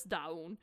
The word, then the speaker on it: DVWN: And then before he went to the military, they announced that Daewoon, which is written as D-V-W-N, but pronounced as Daewoon, an R&B singer, joined the company in 2020.